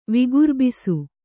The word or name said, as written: ವಿಗುರ್ಬಿಸು